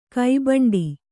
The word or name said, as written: ಕಿಬ್ಬಂಡೆ